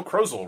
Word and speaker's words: Krozel: Collins and Joe Krozel, rhymes with puzzle.